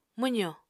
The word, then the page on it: mwzo: Apatani